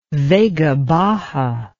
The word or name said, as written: Vega Baja